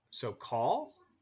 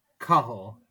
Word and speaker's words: Cathal: Swap your pronunciation of A's. – So call? – Cahal. Cahal. – Cahal. Okay. – So the first A sounds like an O, and the second A sounds like an I. Cahal.